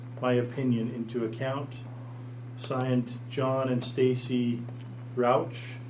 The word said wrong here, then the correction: Rausch: Am I saying that right? – Rausch.